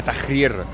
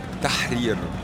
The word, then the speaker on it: Tahrir: Pas Tahrir comme tu le prononces, c'est toi. Tahrir.